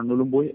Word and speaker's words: Nhulunbuy: con lo cual muchos australianos lo pronuncian Nhalanbay o Nhulunbuy, pero de la forma en la que lo has pronunciado tú, que es como se pronunciaría en castellano Nhulunbuy, es exactamente como se pronuncia.